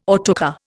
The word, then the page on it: autocar: Indian